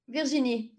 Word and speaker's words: Virginie: Virginie, yeah. – Virginie. Do you find that people who are not French have a hard time pronouncing Virginie?